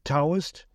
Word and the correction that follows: Todoist: a task management app. It's spelled T-O-D-O-I-S-T. Todoist. Okay, I get it. Todoist.